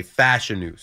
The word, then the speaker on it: Fashanu: I think that, or Fashanu, I was calling him Fashanu, but I heard him say Fashanu. So the emphasis is on the first A.